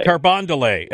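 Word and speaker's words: Carondelet: Carbon delay. – Carbondelet, as opposed to Carbondale. Carbondelet. – Yeah, that's like kind of the faux pretentious pronunciation. It's like calling Target Target.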